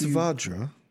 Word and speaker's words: Vajra: It's Vajra. – Vajra. – You know, the A, like the vowel is pronounced like a ah.